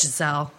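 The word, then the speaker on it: Gisele: And then Giselle was up in the stands, obviously. Like, is that how you say it? – Giselle.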